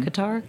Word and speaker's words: Qatar: So we're not only seeing kids in the US, but like we're getting comments from kids like in, I always mispronounce it, Qatar. Mm-hmm. Qatar, yeah.